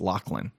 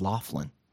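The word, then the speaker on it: Loughlin: If you saw Lori, and I learned that her last name is pronounced Lachlan. I always thought it was Laughlin.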